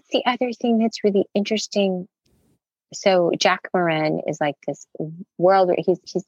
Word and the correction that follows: Morin: And say his name again. – Jack Moran. – Okay. – It's Morin.